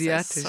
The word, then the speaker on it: Asiatisch: Your 2014 album, Asiatisch, I don't know how to pronounce Asiatisch. – Sorry. Asiatisch.